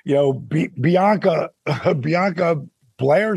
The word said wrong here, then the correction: Belair: is that her last name? – Yeah, Bianca Belair.